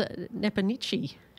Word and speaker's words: Nepomniachtchi: So he's got a match up against Nepanichi. – Yes. Well pronounced. Some people pronounce it Nepomniachtchi, but the Russian commentators go more with Nepomniachtchi.